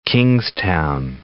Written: North Kingstown

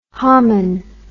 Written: homin

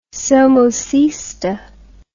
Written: somocista

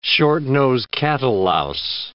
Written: short-nosed cattle louse

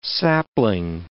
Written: sapling

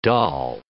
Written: dahl

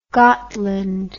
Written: Gottland

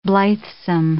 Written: blithesome

